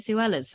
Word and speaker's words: Suella: Suella's for that matter, whose name ironically rhymes with Cruella.